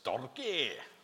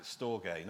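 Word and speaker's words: storge (στοργή): It sounds sort of Scottish. It's storge. That's how you pronounce it, storge. Not with a Scottish accent, but probably with a Greek accent.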